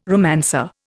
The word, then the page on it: romancer: Indian